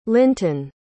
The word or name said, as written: lynton